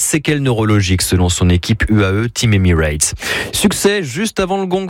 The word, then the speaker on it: UAE Team Emirates: UAE Team Emirates. J'aurais dû prononcer comme ça.